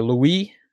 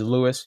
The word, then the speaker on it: Louis: and Napier Louis, who goes by the French pronunciation versus Lewis.